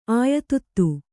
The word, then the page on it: ಆಯತುತ್ತು: Kannada